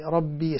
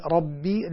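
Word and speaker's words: ربِّ: الحمد لله ربي، سمعتك كانت تقولي رب العالمين، لا، ربي الباء مكسورة، ربي العالمين، الرحمن الرحيم.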